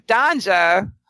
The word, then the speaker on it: Donja: I'm like, how many guys that are heterosexual would be shacked up with Donja, which does rhyme with ganja, Mike.